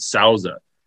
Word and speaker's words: Souza: The guy's name, the owner is David Souza. And it's not spelled the same, but it sounds like the tequila.